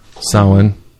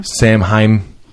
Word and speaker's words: Samhain: the Celtic or pagan holiday of Samhain. Mm-hmm. Samhain. – It's spelled Samhain, like S-A-M-H. – I don't know why it's pronounced that.